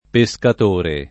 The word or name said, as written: pescatore